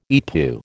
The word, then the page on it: EETPU: American English